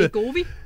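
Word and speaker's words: Wegovy: Det store øh, guldæg jo lige nu, det er det jo det, away, eller hvad det, hvordan den udtales, et fedme... – Vegovi. – Vegovi kan du også ja.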